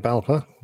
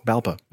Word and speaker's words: BALPA: Pilots require frequent training and recency to be able to fly, says Brian Strutton of the British Airline Pilots Association, or BALPA. Is that the way you'd pronounce that? BALPA? – Yeah, that's right. BALPA. – BALPA.